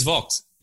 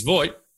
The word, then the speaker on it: Voigt: Jens Vogt. If you say Jens Vogt, no one knows who you're talking about. If you say yep. Jens Voigt, they do.